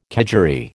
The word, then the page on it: kedgeree: American English